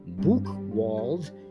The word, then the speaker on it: Buchwald: A German physician, Alfred, I believe it's pronounced Buchwald, and I've been struggling with pronunciations lately for a number of reasons. I'm going to spell that. Last name B U C H W A L D. I would interpret it as Buchwald.